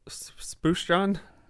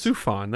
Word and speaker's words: Sufjan: Little, how you say it? Spooshjan? Spooshjan Stevens? – Whoa. Oh. – I thought it was Surf John. – I don't think it's Surf John. Sufjan Stevens? – Sufjan. There you go.